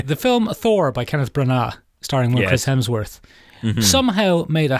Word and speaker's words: Branagh: It's pronounced Branagh.